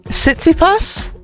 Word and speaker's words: Tsitsipas: A 149-year tennis tradition has finally ended after Stefanos, forgive me, I might say his name wrong, Tsitsipas. What do we think? – Yeah, it's good.